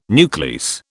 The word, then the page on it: nuclease: British English